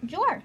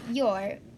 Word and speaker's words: Joar: When I saw this guy's name, it was spelled J-O-A-R, so I pronounced it Jor. Of course I did, but it is your.